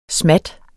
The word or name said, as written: smat